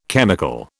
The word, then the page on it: chemical: British English